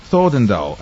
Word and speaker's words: Thordendal: Фредерик Thordendal, am I pronouncing his name right? – Yeah.